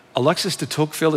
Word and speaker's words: Alexis de Tocqueville: Um, uh, there's a quote from uh, Alexis de Tocqueville, is that how you say yes, his name? Yes, I'm never yes, quite sure how right, to pronounce Alexis it.